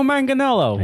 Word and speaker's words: Manganiello: It looks like it's something more than Manganiello, but it's just Manganiello. – Manganiello. – Hey, it's Joe Manganiello. – Yeah. – Looks like it sounds like it's spelled.